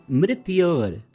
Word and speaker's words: Mrityor: Mitror Mushki. – Mitror Mushki. No, it is Mrityor Mukshya. Mrityor. Yes. Mrityor.